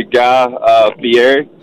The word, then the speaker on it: Guy Fieri: We got Matt Mann's going to be Guy Fieri. I said that right. – Yeah, you did.